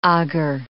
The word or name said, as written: agar